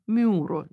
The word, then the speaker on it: миро: Ну, например, слово «синод» да, или слово «миро», вот то масло, которым помазывают да, на царство или при крещении, они звучали как «сюнод» и «мюро». Но ну, я так немножко утрированно это произношу, но все-таки в церковном произношении эти слова звучали иначе.